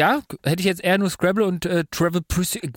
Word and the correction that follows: Trivial Pursuit: Kennst du Travel Pursuit? Kann, ich, Trivial Pursuit. Trivial Pursuit.